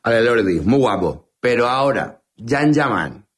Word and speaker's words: Can Yaman: Se escribe Can Yaman, se pronuncia Jan Yaman.